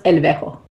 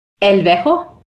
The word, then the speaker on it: LVEJO: Is that Lovejoy? Or how do you say that, actually? – Most people call us El Vejo. – Say it again. – El Vejo. – El Vejo. – Yep, there you go.